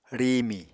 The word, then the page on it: riimi: Apatani